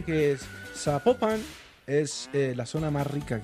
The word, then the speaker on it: Zapopan: Oiga, nos escriben los oyentes y que no se pronuncia Zapopan, sino que se pronuncia Zapopan.